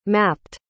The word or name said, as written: mapped